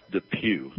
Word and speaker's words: DePuy: You're representing 15 people who received a hip implant made by a company called Dupuy Orthopedics. – It's pronounced Depew. – Depew. Okay. Well, that makes sense. – Although sometimes I say Depuy. – But it is Pew.